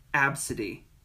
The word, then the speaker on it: Abcde: The child's name is A, B, C, D, E. Absidy. That's how you pronounce it.